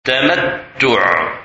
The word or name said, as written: Tamattu